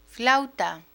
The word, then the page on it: flauta: Spanish